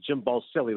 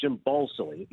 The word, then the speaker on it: Jim Balsillie: They flew me down to Arizona because this was when they thought Jim – I used to pronounce it Jim Balsillie, but it's Jim Balsillie.